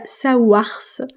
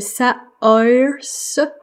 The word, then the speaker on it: Saoirse: Et il y a, alors, je vais prononcer ça à la française parce que euh, quand j'essaye de le prononcer à euh, l'anglaise, ben ça fait un truc dégueulasse. Donc il y a Sawars. Même en français c'est dégueulasse. Parce qu'en fait en anglais ce serait Saoirse.